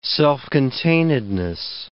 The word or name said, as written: self-containedness